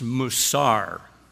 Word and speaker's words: musar: If we go to the Old Testament, the Hebrew word that most closely fits our definition of discipline is musar, or at least that's the transliteration, and it's spelled M-U-S-A-R, musar. In the New Testament, it's the Greek word paideuo, with the transliteration spelled P-A-I-D-E-U-O.